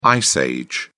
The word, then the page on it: ice age: British English